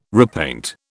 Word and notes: repaint: British English

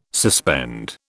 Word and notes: suspend: British English

